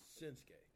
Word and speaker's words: Shinsuke: Is it Shinsuke or Shinsuke? – It's Shinsuke. – Shinsuke. – Okay. The more of them silent letters. – Exactly. Damn Japanese and you silent U's.